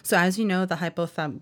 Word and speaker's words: hypothalamus: If he underwent a brain study, he would have probably been identified as having an enlarged hypothalamus.